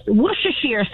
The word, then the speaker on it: Worcestershire: All right, first of all, it's pronounced Worcestershire sauce.